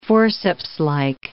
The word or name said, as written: forcepslike